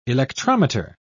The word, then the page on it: electrometer: British English